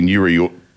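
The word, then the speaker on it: Nuriootpa: Nuriutpa.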